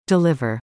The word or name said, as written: deliver